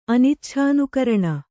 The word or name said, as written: ಅನಿಚ್ಛಾನುಕರಣ